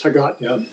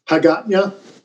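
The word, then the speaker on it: Hagåtña: Capital is Hagatnya. – How do you say that again? – Hagatnya.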